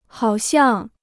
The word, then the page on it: 好像: hǎo xiàng